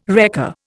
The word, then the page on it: wrecker: Indian